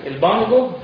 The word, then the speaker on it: البانجو: يبقى انا الحقت البانجو على راي الاخ البانجو بتفخيم الباء البانجو هكذا بهذه الصوره، هكذا نطقها العصر. – هكذا تنطق في العصر.